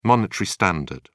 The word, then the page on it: monetary standard: British English